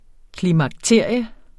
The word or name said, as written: klimakterium